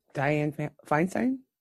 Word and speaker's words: Dianne Feinstein: Yeah, and see, the whole thing with Dianne Feinstein, is that how you say her name? – Yeah.